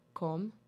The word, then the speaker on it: Come: You've definitely heard her songs before, especially the one I'm going to be playing, which is called Come, C-O-M-E, Come. That's how she pronounce it.